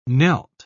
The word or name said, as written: knelt